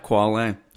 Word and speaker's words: Qualle: Did I say that right? Quale? – Yep, Quale. That's right.